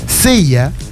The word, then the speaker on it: Ceya: Sella, se escribe C-E-Y-A, Sella, claro.